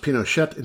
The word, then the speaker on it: Pinochet: I guess it's pronounced Pinochet in Spanish, but I'm habituated to the Frenchified version used by most USians.